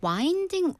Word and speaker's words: winding: But it's, but this is a very common word, like wind, W-I-N-D. Spelling은 wind랑 똑같습니다. – 그죠. 바람 생각해서 발음을 이제 wind로 할 수도 있는데 뒤에 ing가 붙으면 winding.